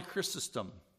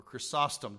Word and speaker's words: Chrysostom: St. John Chrysostom, or Chrysostom, depending how you wish to pronounce it, the name Chrysostom means the golden mouth because of his eloquence in preaching.